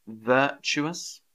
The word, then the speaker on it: virtuous: Virtuous. Why can't I spell it? Virtuous. There we go. Virtuous. There's a schwa again in there. Virtuous. Not virtuous. Virtuous.